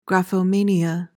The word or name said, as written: graphomania